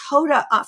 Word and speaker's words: Hoda: And, you know, I want to hit up to talk about the first influencer, Huda, right? Am I saying her name correctly? – It actually wasn't Huda. It was Hoda from the Today Show.